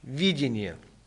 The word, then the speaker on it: видение: Ударение делаем на первую букву «И» – видение.